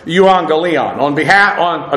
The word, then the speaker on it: euangelion: So on the one hand, from the standpoint of the gospel is actually the preposition kata with the accusative of the term euangelion. According to the standard of the good news, euangelion means exactly that. Euangelion. Again, the double gamma in the Greek is pronounced phonetically ng.